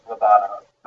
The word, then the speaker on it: Lobanov: The right way, I think, is Lobanov.